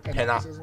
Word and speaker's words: panopticism: Um, just in the process of doing an essay on the topic of, I mean, an article on panoptic, panoptic. I can't pronounce that word right. Uh, pan- panopticism. Panopticism.